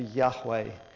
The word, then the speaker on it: Yahweh: It's YHWH. But we believe that the appropriate pronunciation of that would be Yahweh.